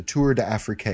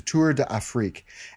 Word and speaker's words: Tour d'Afrique: And of course, I mispronounced it. I called it the Tour d'Afrique. It's the Tour d'Afrique.